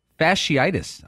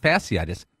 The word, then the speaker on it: fasciitis: Now, Tiger Woods is out of the Hero Open or some big event with plantar fasciitis. I'm told it's pronounced not fasciitis, fasciitis.